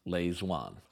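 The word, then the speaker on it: Lê Duẩn: That's spelled L-E space D-U-A-N, but it's pronounced Lê Duan.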